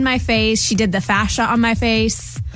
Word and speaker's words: fascia: It was a lymphatic and a, I'm going to pronounce this wrong, fascia. – Fascia.